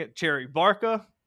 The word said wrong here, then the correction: Barcia: or Cherry Barca. And then eventually someone was like, "Cool, it's Cherry Barcia," and it's a play on the dude from, I already forget the band's name, like the wrongful, I don't even remember it.